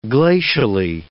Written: glacially

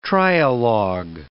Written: trialogue

